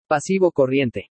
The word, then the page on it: PASIVO CORRIENTE: spanish